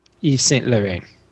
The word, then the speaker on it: Yves Saint Laurent: Yves Saint Laurent.